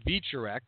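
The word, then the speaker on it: Wieczorek: Adam Vicharek, which I believe is how that's pronounced. That's how they were pronouncing it on commentary. I'm going with that.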